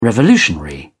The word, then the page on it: revolutionary: British English